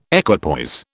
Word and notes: equipoise: British English

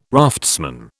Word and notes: raftsman: British English

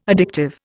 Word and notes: addictive: Indian

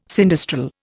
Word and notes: sinistral: Indian

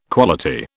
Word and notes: quality: British English

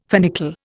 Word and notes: finical: Indian

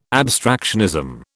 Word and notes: abstractionism: British English

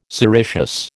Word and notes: sericeous: American English

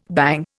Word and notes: bang: Indian

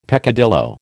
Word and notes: peccadillo: American English

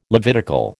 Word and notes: Levitical: American English

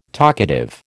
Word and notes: talkative: American English

